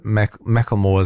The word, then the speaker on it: Macklemore: Yeah, somebody else says that Macklemore is pronounced Macklemore.